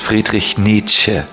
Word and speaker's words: Friedrich Nietzsche: His name is Friedrich Nietzsche.